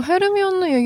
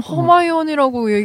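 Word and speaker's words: Hermione: 뭐 헤르미온을 얘기하는 것도 어디서 허마이온이라고 얘기하기를 하질 않나. – 발음상으로는 또 그게 맞으니까.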